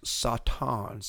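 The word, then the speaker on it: Satan (שטן): It is Satan, pronounced, according to Strong's, Satan, S-A-W-T-A-W-N is the pronunciation that they give for it in Hebrew, and actually occupies three slots in the Strong's, beginning with number 7852, which means, are you ready? – I'm ready.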